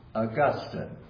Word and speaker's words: Augustine: And then we're going to go back and talk again about another familiar church father, Augustine. Pronounced Augustine, if you will recall.